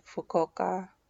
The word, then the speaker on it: Fukuoka: Tokyo and Fukuoka. And that's how you say it.